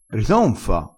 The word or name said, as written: ritonfa